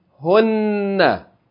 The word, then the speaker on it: hunna: Ha with dhamma, noon with shadda and fatta, hunna. Hunna. Hunna is also a pronoun.